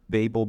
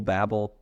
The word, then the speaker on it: Babel: Chapter 11 is basically, you know, there's the Tower of Nations, or the Tower of Babel, after Babel, Babel. I pronounce it both ways in the same sentence, so however you pronounce it is fine.